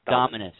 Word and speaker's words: Dominus: people pronounce it different ways, but we're just calling it like Dominion. It's Dominus. – Dominus.